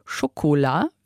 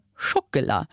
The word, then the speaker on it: Schokolade: Das heißt, wir sagen nicht Schokolade, wie am Französischen, wir sagen Schokolade. Das ist, weil die erste Silbe so stark betont ist, Schokolade, dass die neben drunter mannerkräftig geht.